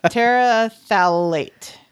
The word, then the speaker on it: terephthalate: Terephthalate.